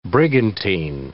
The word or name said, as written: brigantine